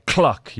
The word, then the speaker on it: Clock: DJ Clock. You got to make sure you pronounce the L there, otherwise it gets very embarrassing.